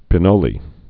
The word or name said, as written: pinole